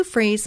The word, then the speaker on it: Fries: And he put in S-U for Sue, and he put in Freeze, F-R-E-E-Z. Okay, so Sue is spelled S-U-E, and my last name is spelt like fries. That's why I say Sue Freeze, spelt like fries, one word, .com.